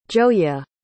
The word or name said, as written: gioia